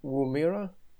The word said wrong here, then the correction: Woomera: Hvordan udtales det? – Det hedder nu Woomera, ja.